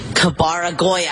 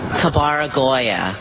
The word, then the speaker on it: Kabaragoya: Kabara Goya. I knew it. I totally knew it. Okay. Kabara Goya. C A B-A-R-A-G-O-Y-A. Kabaragoya. What? – Kabaragoya is spelled K-A-B-A-R-A-G-O-Y-A.